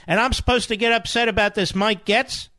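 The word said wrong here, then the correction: Gaetz: How do you pronounce it, Rich? Gates.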